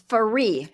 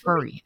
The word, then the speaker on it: Faree: It's Faree. Faree. It's pronounced like referee, except just Faree.